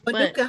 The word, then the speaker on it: Manuka: Manuku? – We absolutely don't think about. – Manuku, Manuku, honey. Oh yes, yes. Manuka I can't honey. pronounce it. – My pronunciations are horrible. – Manuka honey, yes.